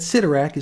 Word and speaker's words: Sidorak: He says that Sidorak is pronounced just like Midorak, so that's a good Stan answer.